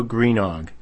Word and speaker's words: Greenough: This was a statue sculpted by Horatio Greenog. I may not have the pronunciation right, but it's spelled G-R-E-E-N-O-U-G-H.